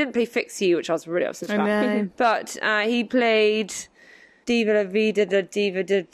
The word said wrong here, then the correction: Viva La Vida: Viva La Vida.